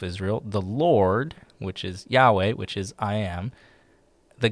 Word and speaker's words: Yahweh: So God's revelation of his name is the name that we pronounce as Yahweh.